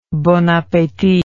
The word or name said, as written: bonappetit